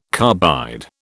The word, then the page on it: carbide: British English